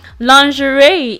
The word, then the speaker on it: lingerie: Now, just before I continue, because we had an English lesson before, lingerie is spelled L-I-N-G-E-R-I-E. Lingerie, okay? Not lingerie. Okay, because some people read it as lingerie. Yeah, I'm not arguing with you about pronunciations. No, I'm not doing that anymore. So it's lingerie.